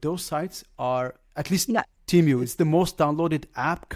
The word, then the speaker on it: Temu: How do you pronounce it? Temu. Probably. – I say Temu. – Temu, yes.